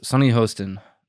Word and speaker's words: Sunny Hostin: Sunny Hostin? Did I say her name? – Yes, Sunny Hostin of the Sunny Hostin.